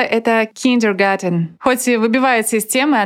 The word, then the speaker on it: Kindergarten: Хоть garten и похоже на английское garden, через букву D в этом слове, я имею в виду в kindergarten, оно сохранило исходный вариант и даже в английском пишется и произносится через T.